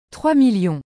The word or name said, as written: 3000000